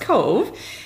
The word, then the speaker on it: KOV: They say, is it K-O-V or is it Cove? What is it? So it's pronounced Cove.